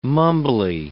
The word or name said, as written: mumbly